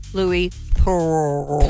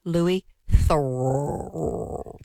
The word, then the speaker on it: Louis Theroux: Louis Theroux. – Please turn the music down, Aaron. And sorry, how do you pronounce it? – Louis Theroux.